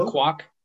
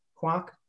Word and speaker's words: Kwouk: Burt Kwouk. – Kwouk.